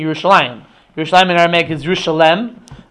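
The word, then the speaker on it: Yerushalayim (ירושלים): So it's spelled as if it was written Yeru and Shalem, but it's pronounced Yerushalayim, right?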